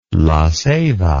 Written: laceiba

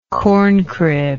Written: corncrib

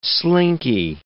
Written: slinky

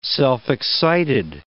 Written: self-excited

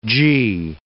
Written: Gee

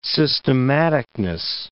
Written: systematicness

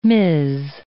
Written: Ms.

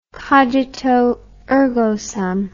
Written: cogito ergo sum

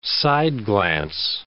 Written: side-glance

